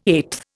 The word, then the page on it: eighth: Indian